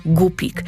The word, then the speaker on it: gupik: Nazwę gupik pisze się przez U, w języku angielskim mówimy guppy, ale po polsku to ciągle jest gupik. Tylko pamiętajcie, tam nie ma głoski ły w środku. To nie jest rybka głupia. O, nie, nie. Rybka jest bardzo sprytna, bardzo mądra. Dlatego gdy wymawiacie jej nazwę, pamiętajcie, żeby tam żadnego ły nie wciskać.